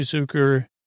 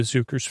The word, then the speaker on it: Zucker: That's how I know how to pronounce it because I would have said Zucker, but he said it's uh, Zucker, Zucker. It was like Zucker, like sucker. So uh, no, so maybe I am saying it wrong. I think it's Zucker though, but it's Z U C K E R because it's not like sucker.